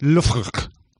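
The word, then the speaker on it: Loughborough: Spelled Labra, but it's pronounced Lufr.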